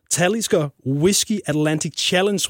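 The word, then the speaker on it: Talisker Whisky Atlantic Challenge: januar, der sejlede du over Atlanterhavet i det, der hedder Talisker Whiskey Atlantic Challenge. Er det sådan, det udtales? – Ja, det er fuldstændig.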